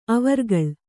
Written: ಅವರ್ಗಳ್

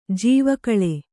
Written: ಜೀವಕಾಲ

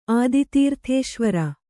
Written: ಆದಿತೀರ್ಥೇಶ್ವರ

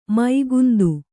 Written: ಮುಕುಂದ

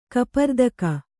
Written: ಕಪರ್ದಿಕ

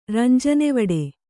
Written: ರಂಜನೆವಡೆ

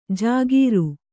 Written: ಜಾಗೀರು

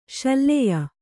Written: ಶಲ್ಯೆ

